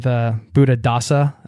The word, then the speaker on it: Buddhadasa: and interactions like this with Buddhadasa, am I saying that correctly? – That's it.